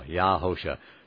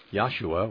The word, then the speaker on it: Yahusha: Right now, Yahusha. Some say Yahshua. Well, that is documented on our website about that word. But why aren't they putting the emphasis on the first syllable, on Yah, instead of the Shua?